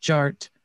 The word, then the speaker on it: DJ Art: DJ Art, with two T's for a double dose of that ting ting. The D is silent, so it's just Jart.